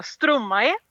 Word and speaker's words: Stromae: Ja, jag hade ju samma problem där, men jag tror att artisten uttalar Stromae.